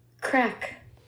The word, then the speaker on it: craic: Crack. – Did you just say crack? Crack. Like C R A C K? – Well, it's spelled C R A I C.